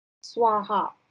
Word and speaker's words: Svaha: Swaha, S V A H A.